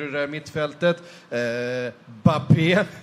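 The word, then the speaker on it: Mbappé: med sitt stumma M i början av allt. Eh, alltså, är det så? Kan vi, få, kan vi få ett riktigt uttal på? – Kylian Bappé. Bappé? Ja, exakt så.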